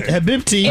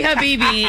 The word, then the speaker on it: Habibi: Habibti. – It's definitely Habibi. It's just the way it's spelled. It's Habibi. It means my love.